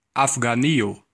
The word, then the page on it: Afghanistan: American English